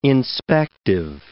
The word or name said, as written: inspective